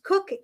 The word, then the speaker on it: Koch: So Jim Koch, it's pronounced Koch. It is spelled the German way, K-O-C-H. Mm-hmm. And it is not named after Jim Koch, because when you spell it K-O-C-H, people can do a lot of different things with the name.